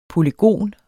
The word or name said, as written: polygon